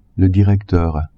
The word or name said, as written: directeur